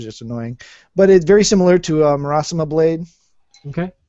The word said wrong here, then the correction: Muramasa: Um, Muramasa blade. Yeah, I mispronounced it.